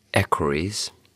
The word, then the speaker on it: equerries: I mean, I spoke to one of his former equerries. Is that how you say it? – That's how you say it.